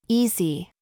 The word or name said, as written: FLEECE